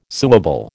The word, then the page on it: suable: American English